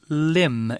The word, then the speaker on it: limb: It's the word limb. It's a noun, and it's spelled L I M B. There's mm -hmm. a mm-hmm. silent B at the end.